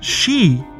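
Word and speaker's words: sídh: The word of the day is she, spelled S-I, often with an acute accent, D-H.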